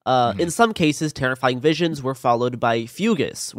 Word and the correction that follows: fugue: which is an old term for, like, fugues. It says fugues. – Yeah, fugue. It's pronounced fugue.